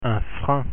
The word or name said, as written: frein